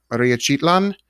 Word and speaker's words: Mariachitlán: Correct me if I pronounce this wrong. Maria Chitlan? – Correct.